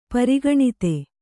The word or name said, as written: ಪರಿಗಣಿತ